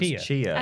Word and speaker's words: Chia: Is it pronounced Tachia? – I think it's pronounced Chia. – I think it's just Chia, but I wanted to, you know, not just say the word.